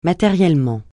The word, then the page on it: matériellement: francais